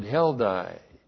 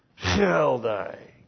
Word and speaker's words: Heldai: Exile number 1, well, we in English pronounce it Heldai. In the Hebrew, it's actually Heldai.